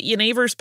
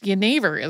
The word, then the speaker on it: Genever: Yeah, because Genever is spelled G-E-N-E-V-E-R. So if you're reading it on a page as an English speaker, it looks like it's Genever.